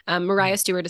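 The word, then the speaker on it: Maria Stewart: Mariah Stewart is spelled like Maria, but that was not how you pronounced it at the time.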